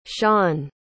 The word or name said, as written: shan